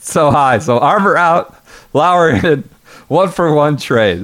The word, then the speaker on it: Lower: Anybody that hasn't watched Justin Lauer, I think it's Lauer, right? – Yeah. – Spelled lower, but it's pronounced Lauer.